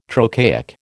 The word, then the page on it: trochaic: American English